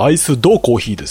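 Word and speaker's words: iced coffee: まあ、うん、でも一応ね、日本語だとアイスコーヒーですけど、正しく発音するならばアイスドコーヒーですよね。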